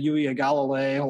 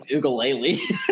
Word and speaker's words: Uiagalelei: I've been working on that pronunciation. – Is that really how you say it? – Yeah. – Uiagalelei. Wow. I thought it was Ugalele.